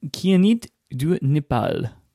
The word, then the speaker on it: Kyanite du Népal: With your pronunciation of the new J. Herbon 1970 ink, what do you got for me? – The J. Herbon 1798 Kianit du Népal. – Wow. I'm impressed. I think you got it.